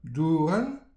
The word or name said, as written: dourenn